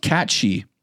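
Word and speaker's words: Cait Sith: It says right here, pronounced catchy.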